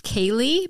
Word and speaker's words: Ceilidh: Pronounced Kaylee, but spelled C-E-I-L-I-D-H, naturally.